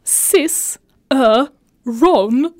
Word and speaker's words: Cicerone: Deras beskrivning är, inom citattecken, Cicerone is pronounced cis, ö, ron. Så e et är tyst.